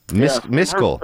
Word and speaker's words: Miscile: M-I-S-C-I-L-E. – M-I-S-C-I-L-E. Miskel. – Her mother was Indian. It wasn't until later that I found out it meant running with other men. – Miskel. M-I-S-C-I-L-E. Miskel.